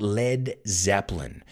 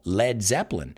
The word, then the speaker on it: Led Zeppelin: Pero, ¿por qué no lo escriben L-E-A-D? Why don't they just spell lead the way it's written? Well, because people would maybe mispronounce it and say lead, porque la palabra lead and lead se escriben igual, L-E-A-D, right? So they were like, we don't want people saying lead, liderar, or llevar por la mano a Zeppelin, queremos que digan lead.